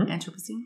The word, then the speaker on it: Anthropocene: Anthropocene.